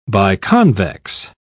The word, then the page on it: biconvex: British English